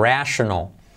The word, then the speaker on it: rational: Se escribe igual, casi igual, pero fijaos la diferencia de pronunciación. Rational.